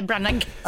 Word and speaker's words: Branagh: Uttalar man GH på slutet? Branagh eller Branagh? – Nej, Branagh, jag tror det är lättare. Det ska vara stumt.